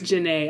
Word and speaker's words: Janae: I think it's like Janae or... – Yeah, it says pronounced Janae at the bottom of the boom.